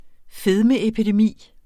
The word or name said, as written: fedmeepidemi